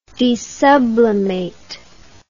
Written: desublimate

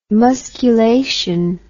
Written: musculation

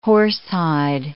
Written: horsehide

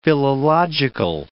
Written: philological

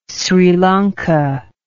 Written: Sri Lanka

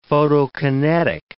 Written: photokinetic

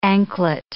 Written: anklet

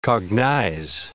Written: cognize

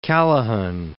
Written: Callaghan

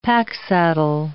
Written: packsaddle

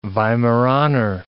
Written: Weimaraner

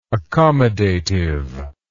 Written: accommodativeness

